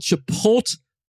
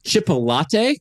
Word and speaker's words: Chipotle: The brand is called Chipotle. – All right. – In the past, everybody listening, I've heard him pronounce it as Chipolate.